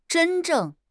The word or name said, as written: zhen1 zheng4